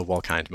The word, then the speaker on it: Wolkind: It's actually a long I, so Wolkind most of the time. But people frequently get it wrong, so I respond to both.